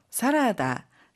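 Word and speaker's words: 사라다 (サラダ): 샐러드는 사라다라고 하는데 모음 전체가 다르게 발음되니 주의해 주세요.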